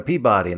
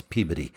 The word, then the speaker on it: Peabody: There's a town in Massachusetts that it took me when I lived out there, like, I'm going to Peabody. And they're like, no, that's Peabody.